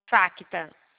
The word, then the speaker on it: Facta: Aí você vai adicionar a facta. – Qual? – Facta.